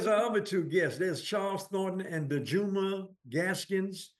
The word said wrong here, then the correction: Danjuma: Did I pronounce that right, sir? – Hey, sir, nice try. It's Danjuma Gaskins. – Danjuma. Well, okay, Danjuma. I like Dejuma. Let's go with Danjuma.